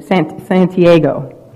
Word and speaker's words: Santiago: I said it right the first time. Should have you. Santiago.